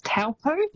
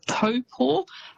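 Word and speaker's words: Taupō: So quite often you'll hear that said as Taupo. – Yes. – It's almost how it's spelt, but that Taupo is actually pronounced Taupo.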